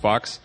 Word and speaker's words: Fuchs: his name is pronounced Fox.